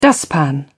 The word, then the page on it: dustpan: British English